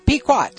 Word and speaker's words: Pequot: Globe Pico, is that how you pronounce that, Pico? Pequot, sorry, Pequot Press.